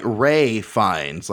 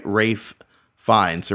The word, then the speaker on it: Ralph Fiennes: But when you say it together, you know, like it sounds like Ray Fiennes, like Rafe Fiennes, or Ray Fiennes.